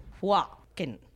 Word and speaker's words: Joaquin: So how do you pronounce it? Joaquin. – Joaquin. – But it's French or it's duck? – No. It's American. – His French name it would be Joaquin.